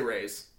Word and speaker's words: Reyes: At Benji Reyes. – At Benji Reyes. – I say it wrong every time. – It's okay. – That's okay. – How do you say it? – I say Reyes.